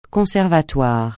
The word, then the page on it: conservatoire: francais